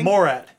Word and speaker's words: Morat: Morat. – Morat. yeah. Morat. Yeah, why is that so hard for me to remember? It's it rhymes with the Borat.